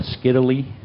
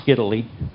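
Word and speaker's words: scytale: The oldest known cryptography device is called a Skiddly. That's the way Wikipedia has it pronounced. It sounds kind of odd to me, but we'll stick with the Wikipedia pronunciation.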